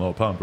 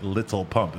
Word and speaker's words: Lil Pump: And she's like, oh, did I say it like that? I'm like, yeah, you said Little Pump. And that was It's not Little Pump.